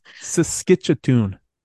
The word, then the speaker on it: Saskatchewan: How does he say it? Why can't I pronounce things right? – Saskatchewan. – Saskatchewan. – Hey, ladies, I'm Saskatchewan. Hey, it's in Canada. – That's perfect.